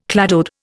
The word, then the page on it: cladode: Indian